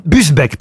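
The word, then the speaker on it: Busbecq: Augier Gilin de Busbeck, pardon, j'ai écorché son nom.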